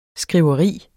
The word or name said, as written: skriveri